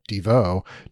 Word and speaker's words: Devo: But I mean, the big thing we have to mention is Devo, which was, you know, kind of, or Devo to pronounce it the way the band prefers, which, you know, so much of its shtick is taken, especially in its early days, is taken from this film.